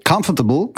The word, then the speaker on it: comfortable: Comfortable, comfortable. – Comfortable. – Czyli tam nie ma R w ogóle prawie, że, tak? Comfortable. – Praktycznie nie i zaczynamy też od K-A.